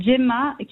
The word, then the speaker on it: Xhema: Mon nom d'artiste, c'est Gemma, qui s'écrit X-H-E-M-A. – D'accord. – Et moi, c'est Bino, donc B-I-N-O.